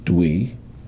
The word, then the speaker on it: Tui: So, Dwi is T-U-I, pronounced Dwi. And Li is just L-I, easy to do. And Chen is C-H-E-N, Chen.